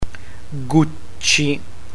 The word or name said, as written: gucci